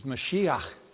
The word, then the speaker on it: Mashiach: The Hebrew word for anointed is Mashiach, which we usually pronounce as Messiah.